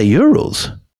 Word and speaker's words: Urals: Is it the Urals or is it the Urals? – I say Urals. – You said Urals.